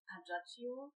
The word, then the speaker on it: Ajaccio: Wie, wie spricht man das aus? Ajaccio. – Oder so, ja.